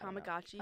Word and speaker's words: Tamagotchi: Tamagotchi. – Tamagotchi.